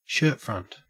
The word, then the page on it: shirtfront: Australian English